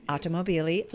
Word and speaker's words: Automobili: Automobili, Lamborghini, from 05 to 2016, back in the top spot.